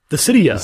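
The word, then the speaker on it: Dissidia: So he's winning Dysdeia? How do you say that shit? – Dysidia, Final right.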